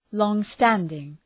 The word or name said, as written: long-standing